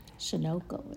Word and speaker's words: Sunoco: Sunoco, it's pronounced.